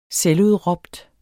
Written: selvudråbt

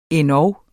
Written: endog

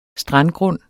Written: strandgrund

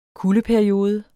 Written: kuldeperiode

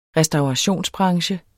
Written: restaurationsbranche

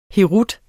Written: herut

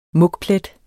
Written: mugplet

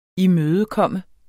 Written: imødekomme

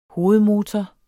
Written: hovedmotor

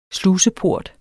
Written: sluseport

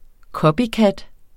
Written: copycat

